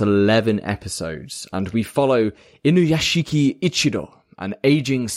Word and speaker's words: Inuyashiki: But we are watching this week Inuyashiki.